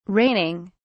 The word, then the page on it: raining: British English